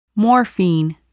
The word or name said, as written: morphine